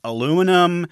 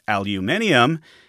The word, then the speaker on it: aluminium: First, he liked alumium, then aluminum. The Americans picked up on that spelling and pronunciation, but the British scientist argued it should be aluminium, and they stuck to their guns.